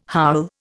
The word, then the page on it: howl: Indian